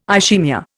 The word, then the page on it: ischaemia: Indian